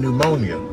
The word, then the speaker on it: pneumonia: Why is that P there in pneumonia? – It is very unnecessary.